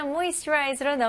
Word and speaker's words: moisturize: 네, 여기서도 조금 어려운 부분이 있어요. 중간 부분인데요. 여기 S에서 T에서 U로 넘어가는 거예요. 여기서는 moisturize. 그래서 S에다가 약간 CH 발음이 나와야 돼요. Moisturize. Moisturize.